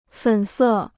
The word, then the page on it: 粉色: fěn sè